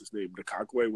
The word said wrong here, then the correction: Ngakoue: Yeah, Ngakoue.